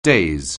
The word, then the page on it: daze: anglais